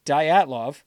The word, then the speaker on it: Dyatlov: Pronounced Dyatlov.